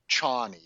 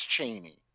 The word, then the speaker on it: Chani: It's not Chani, it's Chaney.